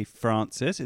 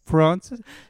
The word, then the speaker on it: Francis: And when I was here before, I actually changed my name to Frank because I really can't stand the way Americans say Francis. It's like they're trying to do an English accent.